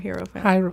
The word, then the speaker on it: Hierophant: Do you say Hierophant or Hierophant? – I say Hierophant.